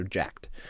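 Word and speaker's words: Jack3d: So Jack 3D, just to give people a preface, Jack 3D is, it's spelled Jack, like the name Jack, J-A-C-K, 3D, so jacked.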